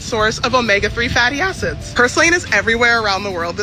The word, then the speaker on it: purslane: Purslane, am I saying it right? – Purslane, yeah.